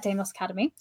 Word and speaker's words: Deimos Academy: But what we're here to talk about is Deimos Academy. Did I say that right? – Yes. – Yeah, that's pretty simple. So we're here to talk about Deimos Academy.